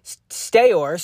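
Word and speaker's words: Steuer: Patricia Leary Stewart, uh, Stewart accused, I'm sorry if I'm butchering the pronunciation of that last name, S T E U S T E U E R. Steyer,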